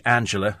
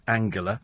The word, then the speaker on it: Angela: Any other country, Angela. Apparently in Germany it's Angela.